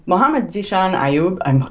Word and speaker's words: Mohammed Zishan Ayub: Mohammed Zishan Ayub, I hope I'm pronouncing his name right. – That was good.